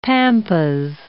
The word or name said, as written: pampas